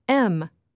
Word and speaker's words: 'em: But when saying this expression, we don't say them. We say the informal shortened form of the word, pronounced M. And we say it quickly, blending the words together, knock 'em dead.